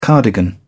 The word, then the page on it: cardigan: British English